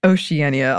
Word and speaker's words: Oceania: Oceania, although I think that is specific pronunciation and it's not the American English pronunciation.